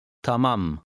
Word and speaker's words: Tamam: Read number 7, stressing the second syllable, as is usual in Turkish. – Tamam.